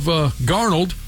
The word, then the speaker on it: Darnold: First off, he put a late hit on Ducks quarterback Sam Darnold. How do you say it? – Darnold.